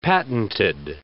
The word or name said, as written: patented